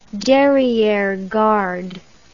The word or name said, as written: derriere garde